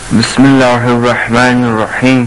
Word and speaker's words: بسم الله الرحمن الرحیم: بسم الله الرحمن الرحیم. – بسم الله الرحمن الرحیم. – بسم الله الرحمن الرحیم. – خیلی عجله میکنید، بخش بخش مثل من بخونید. بسم الله الرحمن الرحیم.